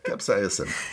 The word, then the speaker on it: capsaicin: Capsaicin. – As he said, what he said is another non-taste. And this is the burning, it's the burning of capsaicin. Did I say it correctly? – Perfect. Yeah.